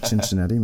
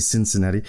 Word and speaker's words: Cincinnati: c'est pas Cincinnati, mais c'est Cincinnati.